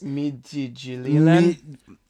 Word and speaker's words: Midtjylland: It is Midtjylland.